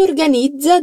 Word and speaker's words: organizza: Chi organizza dei viaggi? Di nuovo, la parola organizza, invece, ha una pronuncia della Z diversa in questo caso.